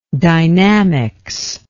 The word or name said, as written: dynamicist